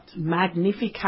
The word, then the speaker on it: Magnificat: Magnificat.